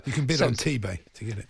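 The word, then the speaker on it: Tebay: T-Bay, is that how you pronounce it? – T-Bay Service Station.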